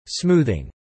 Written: smoothing

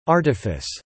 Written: artifice

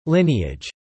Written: lineage

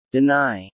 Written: deny

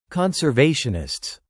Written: conservationists

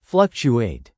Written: fluctuate

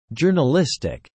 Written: journalistic

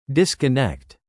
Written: disconnect

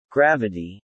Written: Gravity

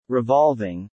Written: revolving

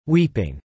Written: weeping

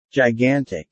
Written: gigantic

